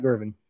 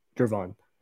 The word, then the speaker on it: Gervon: So Gervon Dexter, got to say his name right, not Gervin, Gervon.